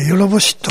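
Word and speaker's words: 弱法師: まあ上にヨロってのす、ヨロってのは漢字で書くとですね、普通では読めません、弱いという、強い弱いの弱い方子と書いて、ヨロボシと発音するんですけれども、まあ、この詳しい話は、あとは時間あったらもうちょっとしますけど、よろぼしという風なに呼ばれる、謡曲の一つです。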